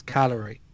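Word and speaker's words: Cagliari: It's Calgary. – Calgary. – You don't pronounce the G.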